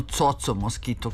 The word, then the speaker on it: Coco Mosquito: U pesmi Na plaži slušali smo zagrebački bend Jinx, koji je osnovao gitarista Coco Mosquito, odnosno Coco Mosquito, kako on kaže da treba da se izgovara,